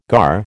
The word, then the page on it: gar: American English